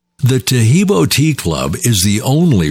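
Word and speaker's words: Taheebo: Tajibo is spelled T-A-H-E-E-B, like boy, O, then the word tea, and then the word club.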